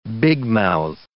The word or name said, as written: bigmouthed